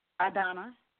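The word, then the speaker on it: Adonna: Adana, how do you spell that? – A is in apple, D is in David, O N N A. – Oh, Adana. Okay. It's like Madonna without an M. – That's right.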